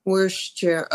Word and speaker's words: Worcestershire: But no, Worcester, that's only the city in Massachusetts. Worcester. The rest of it's Worcestershire. Okay.